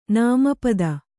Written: ನಾಮಪದ

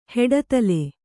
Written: ಹೆಡದಲೆ

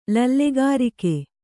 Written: ಲಲ್ಲೆಗಾರಿಕೆ

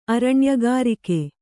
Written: ಅರಣ್ಯಗಾರಿಕೆ

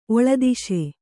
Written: ಒಳದೇಶ

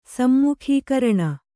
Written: ಸಮ್ಮುಖೀಕರಣ

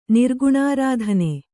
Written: ನಿರ್ಗುಣಾರಾಧನೆ

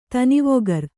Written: ಧನವಿಕಾರ

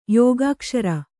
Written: ಯೋಗಾಕ್ಷರ

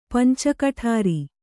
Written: ಪಂಚಕಠಾರಿ

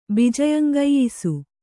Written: ಬಿಜಯಂಗೆಯ್ಸು

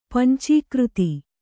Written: ಪಂಚೀಕೃತಿ

